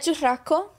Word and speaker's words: churrasco: So we had churrasco. We had churrasco with con gris. – Well, you can roll your R. You can roll your R, right? – Of course.